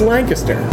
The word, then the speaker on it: Lancaster: Oh, wait, let's ask the locals how to pronounce it. – It's Lancaster. Lancaster. ster. – Yeah, it's Lancaster, Pennsylvania, that is.